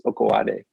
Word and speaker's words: Okunade: It's spelled Okunade, but it's Okuade.